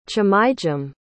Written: chimaijem

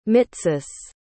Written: mitsis

